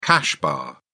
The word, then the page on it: cash bar: British English